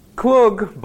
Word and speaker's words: Kluge: Kluge, you pronounce it? K-L-U-G-E, yes.